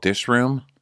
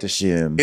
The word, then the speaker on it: Dishoom: Wasn't it eventually called Dishroom? Or was it called Dishoom? – She's like, we don't pronounce the R in England. We just call it Dishoom. – It's silent here.